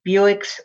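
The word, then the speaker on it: Bewick's: The maker is the plucky Buick's Wren. That's pronounced like Buick the car, but it's spelled B-E-W-I-C-K, for an ornithologist friend of John James Audubon.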